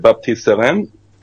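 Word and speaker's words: Baptiste Serin: But the guy on the bench, Baptiste Seren. Oh, did you hear my pronunciation there? Damn, sounded good. – It did. I'm very impressed.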